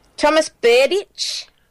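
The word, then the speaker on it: Tomáš Berdych: Thomas Berdych.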